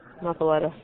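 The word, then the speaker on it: muffuletta: But I tell you, if you've never had an Italian muffalato, am I saying that right? Muffalato? Muffalata? – Muffaletta.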